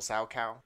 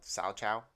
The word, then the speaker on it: Salchow: I love a triple sow cow, which I just found out is spelled sow chow. – Oh, okay. – S-A-L-C-H-O-W is how you spell sow cow. – C-H-O-W. – Yeah. – Wow. So the H is silent.